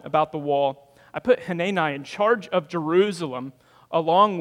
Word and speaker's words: Hanani: He said, it's Hanani. I was saying Hanini, like panini.